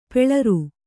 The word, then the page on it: ಪುಲ್ಲೋರಿ: pullōri; noun